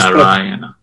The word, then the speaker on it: Narayana: And Shakshi Gopal says South Indian pronunciation is full Narayana. And if I understand, the first two A's are long, right? – Yes, Narayana.